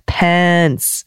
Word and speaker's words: Pence: I had discovered that kiddo thought that then vice president's last name was Pants, Mike Pants. So I very carefully worked with him. Pence. By the end of the conversation, my child had carefully updated his pronunciation, diligently switched to that short E, but now is calling him Mike Pencil.